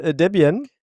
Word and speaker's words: Debian: So, das lustige Thema ist Debian.